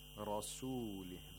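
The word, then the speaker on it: رسوله: Rasulih. The ra has to be pronounced with tafkhim, with thickness.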